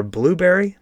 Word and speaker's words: Blubrry: So click on add new in the plugin section, then search for Blueberry PowerPress plugin, and it's spelled kind of funny. It's Blubrry, so make sure that you type it in correctly or just search for PowerPress, and then click to install that plugin.